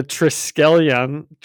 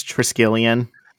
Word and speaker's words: Triskelion: And we open on the Triskelion. – I think it's Triskelion. – Yeah, I know this. I do love seeing it. I wish I gave it a better pronunciation there to start,